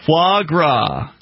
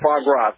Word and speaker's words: foie gras: I was listening to the podcast July 17th, and Derek Jay was on, and you guys were talking about the business that sold the Fourier, you know, the ducks that are force-fed. – Foie gras. – Okay. – Oh, yes. Foie gras. Thank you. I don't know how to say it.